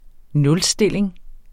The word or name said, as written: nulstilling